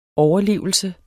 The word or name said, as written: overlevelse